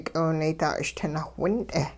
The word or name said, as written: k'iwunaydaa-ch'itinaawh wint'e